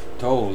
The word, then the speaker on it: Tolle: Eckhart Tolle. I believe I pronounced that right. Eck, Eckhart Tolle, yeah.